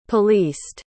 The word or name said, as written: policed